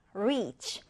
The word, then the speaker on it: reach: reach. 자, reach라는 이 단어는요. 자, 일단은 여러분 rich라는 단어와 비교해 주세요. rich 하면은 부유한이잖아요. 이거는 짧은 아이 발음입니다. 그래서 rich, rich인데 이 reach라고 하는 것은 긴 아이 발음이에요.